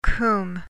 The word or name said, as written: cwm